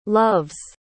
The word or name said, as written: love's